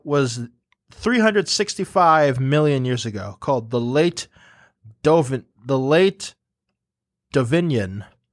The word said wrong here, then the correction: Devonian: I feel embarrassed because I was literally pronouncing these all correct last night. Now I'm not entirely sure. But it's the Late Devonian extinction, and there are two theories about it.